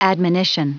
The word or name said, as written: admonition